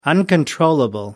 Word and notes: uncontrollable: American English

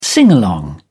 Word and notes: singalong: British English

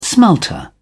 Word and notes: smelter: British English

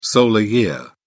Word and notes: solar year: British English

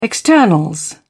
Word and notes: externals: British English

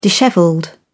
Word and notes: dishevelled: British English

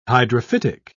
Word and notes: hydrophytic: British English

